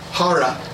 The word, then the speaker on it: Jara: Jara. Shouldn't be an idiot. Say it right.